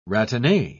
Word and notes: ratine: British English

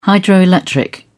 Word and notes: hydroelectric: British English

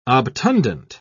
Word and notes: obtundent: British English

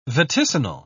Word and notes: vaticinal: British English

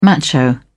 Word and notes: macho: British English

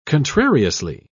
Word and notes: contrariously: British English

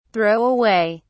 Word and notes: throw away: British English

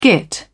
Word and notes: git: British English

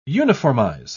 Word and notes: uniformize: British English

